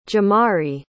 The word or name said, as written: ja'mari